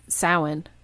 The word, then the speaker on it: Samhain: He talked about Halloween, you know, and we tend to call it Samhain, but he called, you know, pronounced it the right Celtic way, Samhain.